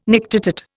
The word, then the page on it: nictitate: Indian